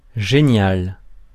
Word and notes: génial: France; [ʒe.njal]

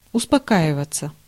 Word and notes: успокаиваться: [ʊspɐˈkaɪvətsə]

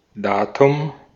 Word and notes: date: /deɪt/; American English; [deɪt]

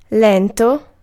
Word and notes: slow: English

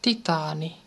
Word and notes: titaani: [ˈt̪it̪ɑːni]

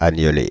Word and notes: agneler: [a.ɲə.le]; Paris

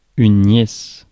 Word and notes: nièce: [njɛs]; France

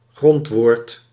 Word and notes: stem: /stɛm/

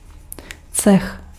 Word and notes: cech: /tsɛx/

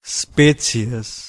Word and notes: quality: American English; /ˈkwɑl.ɪ.ti/

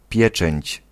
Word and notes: seal: /siːl/

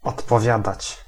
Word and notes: odpowiadać: [ɔt̪pɔˈvʲjad̪at͡ɕ]

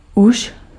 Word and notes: al: /ɑl/

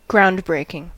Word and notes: groundbreaking: American English; [ˈɡɹaʊnd.ˌbɹeɪ.kɪŋ]

